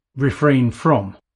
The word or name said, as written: refrain from